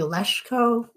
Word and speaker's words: Lesko: Well, it's technically Leshko, but nobody says it like that.